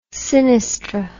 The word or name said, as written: sinistr